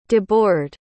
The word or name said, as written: debord